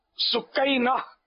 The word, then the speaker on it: سکینہ: یہ ہمارے یہاں معروف تو سکینہ ہے، ویسے سکینہ اصل تلفظ یہی ہے.